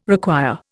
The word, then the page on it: require: Indian